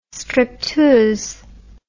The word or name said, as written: stripteuse